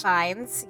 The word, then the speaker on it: Fiennes: Ralph Fiennes, or is that pronounced Ralph Fiennes? – Ralph Fiennes, yes. Yeah.